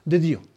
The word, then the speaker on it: des dieux: Festín des dieux, ¿cómo se dice? Festín. – De Dieu. – De Dieu, exactamente.